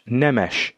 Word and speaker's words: Nemes: No, it's Nemes. I'm Romanian, but my name is Hungarian, and it's pronounced Nemes. – Nemes.